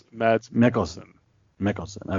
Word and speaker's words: Mads Mikkelsen: So I'm going to probably butcher his name, but so they announced that Mads Mikkelsen, is that right? – Mads Mikkelsen, okay.